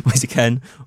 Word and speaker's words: Moise Kean: Up front, it's either, I can never say his name, but Moise Kean, is that right? – Yeah, okay. Moise Kean or Dominic Calvert-Lewin, two completely different players.